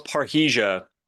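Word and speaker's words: parrhesia: Is that how you say it? – Yeah, it's parahesia.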